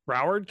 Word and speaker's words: Broward: Miami Dade at 334, Broward Broward County.